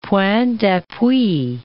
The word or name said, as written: points d'appui